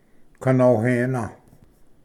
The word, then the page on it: ᎦᏃᎮᎾ: ganohena